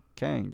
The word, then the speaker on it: Kang: Krang the Conqueror. Did I say his name right? Kang or Krang? – Kang. – Kang, not Krang.